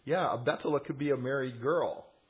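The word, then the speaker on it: Betula (בתולה): The technical word for virgin, meaning a maid that's never been with a man, that's Betula in Hebrew, or Bethula, but the H is silent generally in modern Israeli or Hebrew pronunciation.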